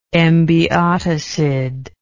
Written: embiotocid

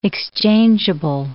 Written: exchangeable

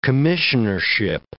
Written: commissionership

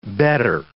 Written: better

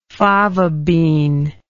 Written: fava bean

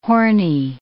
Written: horny

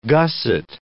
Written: gusset

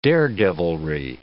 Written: daredevilry

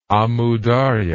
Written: Amu Darya

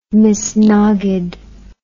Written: misnaged

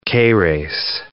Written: Keres

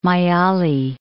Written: Mayyali

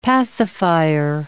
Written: pacifier

